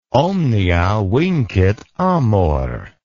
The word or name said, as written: omnia vincit amor